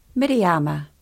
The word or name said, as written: Miriama